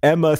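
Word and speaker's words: Emmma: making my legal name Emma that is Emma spelled with three M's E M M M A.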